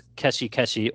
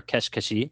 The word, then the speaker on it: Keshi Keshi: Today, we're going to be talking about a Dragon Quest puzzle game called Dragon Quest Keshi Keshi, or Kesh Keshi, as they pronounce it in the game.